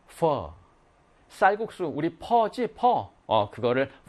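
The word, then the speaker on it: phở: 이제 우리가 배웠으니까 베트남 식당 가면은, 베트남 음식점 가면은, 퍼 주세요. F 발음. 퍼.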